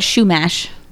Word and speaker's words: Chumash: and I am from the Chumash. I'm sorry. I don't know if I'm saying that right. – Well, tribe? According to the episode of Buffy Shumash that I remember, tribe? They said Chumash tribe.